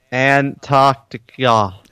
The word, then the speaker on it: Antarctica: Guys, there's the T. Antarctica. – Antarctica. – Like, why do they say Antarctica? – It's Americans.